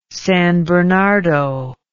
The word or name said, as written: San Bernardo